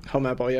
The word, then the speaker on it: Helmeppo: Shout out to Helmeppo. I think I said it right this time. – Helmeppo, yeah.